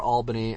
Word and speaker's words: Albany: or Albany as it's correctly pronounced.